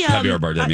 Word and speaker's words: Javier Bardem: And Javier Bardem, did I say that right? – Javier Bardem, yeah.